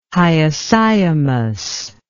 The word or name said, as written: hyoscyamus